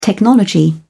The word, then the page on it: technology: British English